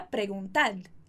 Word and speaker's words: perguntad: Então, perguntar vai virar perguntad, perguntad. Ok? Muito cuidado com a pronúncia, perguntad.